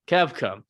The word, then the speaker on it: Koepka: Koepka.